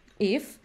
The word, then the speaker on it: IF: I can just add that the name of our model, IF, is inspired by the song If by Pink Floyd. – Very cool. I was wondering. – Yes, I think everybody. – So you pronounce it just if, that's cool.